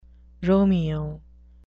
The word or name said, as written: romeo